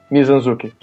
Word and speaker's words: Mizanzuki: E também o Ivan Mizanzuki, que do jeito que se escreve, não parece que é o jeito que se pronuncia. Mizanzuki. Que ele falou que adorou, pra gente continuar com um ótimo trabalho, que ele tava muito afim de escutar o 3.